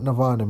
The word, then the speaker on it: Nirvana: And what Nirvana means is actually when we look down at it into the original language of ancient Sanskrit, it consists of three syllables, nir, va, na.